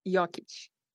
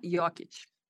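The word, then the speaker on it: Jokić: Nikola Jokic. – Okay, one more time. – Nikola Jokic. – Okay, Jokic. That's even a little bit like the vowel sounds like an A-W almost more than an O.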